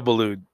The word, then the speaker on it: Boulud: Uh, coming back, we're going to talk about continue talking about Christian and his time at DB David Beaulieu Danielle. Daniel Boulud. Okay, Daniel Boulud. – I could never say that right.